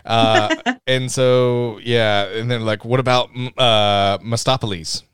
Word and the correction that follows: Mephistopheles: And he goes, I will correct your pronunciation on that. – What is it? – It's Mephistopheles. – Mephistopheles? – Yeah. Mephistopheles, which is spelled like M-E-P-H-I-S-T-O.